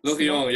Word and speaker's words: Lorient: shoot me if I say this wrong, Lorient France. – Lorient, yeah.